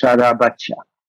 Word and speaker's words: Sarabaca: Well, yeah, you say it okay if you well, you speak English, okay. but it's actually Sarabacha.